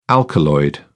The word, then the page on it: alkaloid: British English